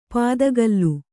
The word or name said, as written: ಪಿತ್ತಕಲ್ಲು